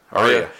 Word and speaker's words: Arya: No, the funniest thing is, though, when Ari finally gets her... Or am I saying her name right? – Aria.